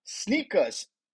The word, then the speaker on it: SNKRS: Nike's digital app, Sneakers, the pronunciation is S-N-K-R-S. It's pronounced as sneakers, but it's written as S-N-K-R-S,